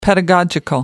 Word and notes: pedagogical: British English

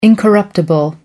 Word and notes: incorruptible: American English

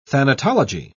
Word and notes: thanatology: British English

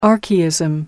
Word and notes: archaism: American English

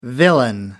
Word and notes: villain: American English

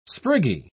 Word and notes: spriggy: British English

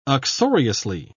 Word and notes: uxoriously: British English

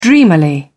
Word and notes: dreamily: British English

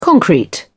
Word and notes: concrete: British English

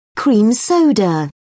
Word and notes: cream soda: British English

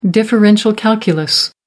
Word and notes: differential calculus: American English

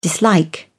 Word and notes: dislike: British English

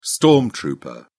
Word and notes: storm trooper: British English

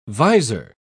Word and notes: vizor: British English

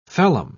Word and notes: phellem: British English

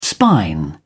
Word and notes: spine: British English

